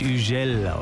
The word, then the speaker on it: Hugel: Das ist ein französischer DJ, deswegen heißt er nicht Hugel, so wird er nämlich geschrieben. Er wird Hugel ausgesprochen. Schon klingt's viel cooler.